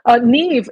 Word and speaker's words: Niamh: Neave in Irish is spelled, I had to look it up, N-I-A-M-H. Nobody would know that. Oh, that's right. It's the one that looks like Neum with an H at the end, but it's Neave.